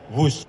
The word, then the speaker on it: Whoosh: Dalam acara peresmian pada hari Senin 2 Oktober, Presiden Jokowi mengatakan kereta cepat pertama di Asia Tenggara ini dinamakan WUS. Menurutnya nama tersebut terinspirasi dari suara yang terdengar melesat dari kereta berkecepatan tinggi dan merupakan cerminan dari waktu operasi optimal sistem hebat. – Sekali lagi, W-H-O-O-S-H. Tapi membacanya WUS.